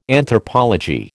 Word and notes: Anthropology: American English

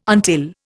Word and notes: until: Indian

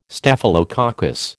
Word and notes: Staphylococcus: American English

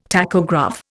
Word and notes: tachograph: Indian

